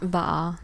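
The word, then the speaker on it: Va'a: Man hört immer so viel von Kanu, Kajak und ihr habt noch eine andere Bootskategorie, wie spricht man sie aus? – Waa. – Waa, okay. – Genau, die kommt aus dem Hawaiianischen, glaube ich.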